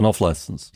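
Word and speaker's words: off-licence: It's the stress on the off rather than on-license most people go with. – An off-license? – Yeah, there you go.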